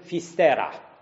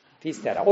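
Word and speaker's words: Fisterra: Dar el scriu într-una, iar galicienii care sunt stăpâni în această provincie spun Fistera. Fistera.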